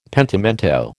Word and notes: pentimento: American English

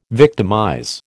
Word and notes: victimize: American English

